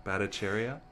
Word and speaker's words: Batacheria: So phonetically, you just gotta go bat, a, cherry, a. And just push those together. Batacheria.